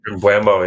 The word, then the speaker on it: Mbeumo: is it Mbeumo? – Mbeumo, yeah.